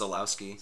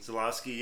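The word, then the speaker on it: Żuławski: I don't exactly know how to pronounce the name. I believe it's Zalowski. – Zalowski, yeah. I don't know.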